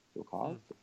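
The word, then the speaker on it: Fialkov: Filkov, I'm pronouncing that right, right now.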